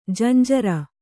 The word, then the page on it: ಜುಂಜುರು: junjuru